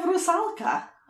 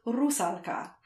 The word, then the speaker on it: Rusalka: Now I'm going to go my diction bent for two seconds here, because in Czech, her name is Rusalka. And I have spent many, many years trying to get the stress on the first syllable. – Mm. – In Russian, her name is actually Rusalka. – Interesting. – So when you're saying Rusalka in English, you're saying it more like the Russians do and not like the Czech people do.